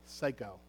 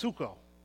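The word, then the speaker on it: suko (ψύχω): The Greek word for cold is, and I'm not kidding about this, psycho, or it's pronounced suko, which means to breathe, to blow, to cool by blowing, to be made cool or cold.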